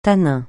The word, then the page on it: tanin: francais